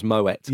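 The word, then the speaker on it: Moët: It's like it's like Moe is meant to be pronounced Moet. – Yeah. You just but you sound like a fool if you call it Moet.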